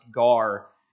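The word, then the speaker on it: gar (γάρ): The word for there is a Greek word it's pronounced gar, and it's a justification word.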